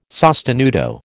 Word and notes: sostenuto: American English

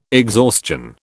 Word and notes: exhaustion: British English

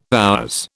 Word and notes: HR: British English

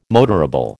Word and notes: motorable: American English

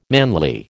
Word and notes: manly: American English

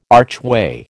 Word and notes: archway: American English

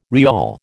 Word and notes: rial: American English

